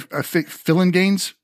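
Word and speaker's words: Phillinganes: And then it's Greg Fillinganes, is how you pronounce his name.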